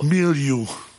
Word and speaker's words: milieu: M-I-L-I-E-U. – Milieu.